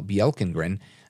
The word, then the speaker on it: Bjelkengren: So I'm just really taken aback here that this – and she's a judge right now, Charnel Bjelkengren, and that's a soft J like the European way, B-J-E-L-K-E-N-G-R-E-N.